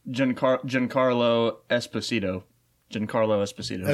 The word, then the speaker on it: Giancarlo: So then we also have Moff Gideon being played by, I'd assume you pronounce it this way, Giancarlo Esposito. Oh, that's like John Carl.